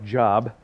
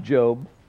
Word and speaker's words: Job: Or if you look at the Old Testament, it looks like Job. It's pronounced Job.